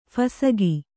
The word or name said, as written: ಫಸಗಿ